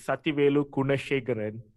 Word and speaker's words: Sathyvelu Kunashegaran: The full form of it is Sathyvelu Kunashegaran.